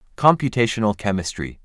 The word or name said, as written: Computational chemistry